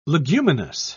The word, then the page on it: leguminous: British English